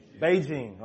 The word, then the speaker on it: Beijing: Beijing.